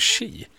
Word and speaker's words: Xi: Ja, nu så sa du Si, tror jag, istället för Xi.